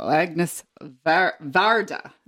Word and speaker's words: Agnès Varda: directed by the incredible Agnès Varda. Am I saying that right? – Yes.